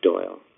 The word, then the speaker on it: Doyle: Pronouncing my last name is easy to do because it's the same last name shared by the author, Sir Arthur Conan Doyle of Sherlock Holmes,